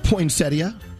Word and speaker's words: poinsettia: Spelled poinsettia. Oh. The proper pronunciation, by the way. – P-O-I-N-S-E-T-T-I-A. Poinsettia. – Yes.